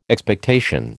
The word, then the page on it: Expectation: American English